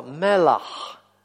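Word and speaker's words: мелах (מלח): Вот первое слово «мелах», второе «мелех». Слышите разницу? «Мелах», «мелех». То есть первое у нас «хэйт» на конце, «мелах», гортанный звук.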